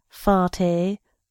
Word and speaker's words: Farte: He pronounces his name Farte with a great French stress on the last syllable.